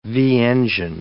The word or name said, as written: V-engine